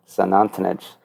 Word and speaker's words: Xunantunich: It's that starts with an X, so I always was like, are they saying St. Anthony's? And no, it's pretty close to pronounced San Antonich.